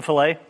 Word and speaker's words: fillet: This strip of fish can have a double L and a sounded T. McDonald's prefers a single L and silent T. – What's a fillet? – Yes.